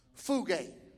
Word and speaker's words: pheugo: It's spelled P-H-E-U-G-O. And pronounced fuge.